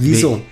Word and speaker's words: Wizo: Oder wie spricht man es aus? – Wieso? – Wieso, W-I-Z-O.